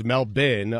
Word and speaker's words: Melbourne: Well, I thought it was Melbourne when we started today, but it's Melbourne, and that is courtesy of Noah Eagle. – Yeah, so Melbourne. The Australian pronunciation, Sal, is Melbourne.